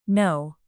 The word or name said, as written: know